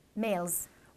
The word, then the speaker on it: mal: I will add to this evil identification that one should look for this male incarnation. Male and evil, incidentally, are both pronounced mal in French.